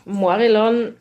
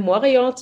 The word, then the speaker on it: Morillon: Wird das so ausgesprochen, Morillon? – Und da gibt's, man kann aus dem Chardonnay ja Chardonnay machen und aus dem Morillon, die Aussprache, da mische ich mich nicht ein. Also wir sagen Morillon zu diesem Wein.